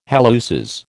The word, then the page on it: halluces: American English